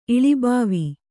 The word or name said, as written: ಇಳಿಬಾವಿ